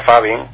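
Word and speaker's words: phubbing: Hemos descubierto a los caras pálidas y bueno, otros conceptos que aparecen en tu libro, no sé si dice Fubin o Fabin, ¿qué es eso del Fubin o Fabin? – Se pronunciaría Fabin.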